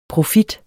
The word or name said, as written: profit